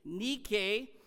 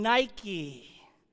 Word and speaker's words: Nike: And so they chose the Greek word for victory, Nike. And they changed the pronunciation to make it a little more palatable for English speakers, and it became Nike.